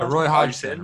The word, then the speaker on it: Hodgson: How do you pronounce it? – Roy Hodgson. Roy Hodgson. That's just Hodgson.